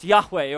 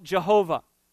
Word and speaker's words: Yahweh: In the original language, it's pronounced Yahweh, or some people call it Jehovah.